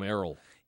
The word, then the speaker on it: Errol: Errol Brown is his name. – Is that how he pronounces it? – Is it Errol? – I would call him Errol. – E-R-R-O-L. – That's Errol. – Errol. Okay.